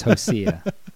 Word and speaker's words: Hosea: Did I not say Hosea? – Yeah, that's right. – That's not the proper pronunciation. It's Hosea. I always hear people say Hosea, and it just makes me cringe.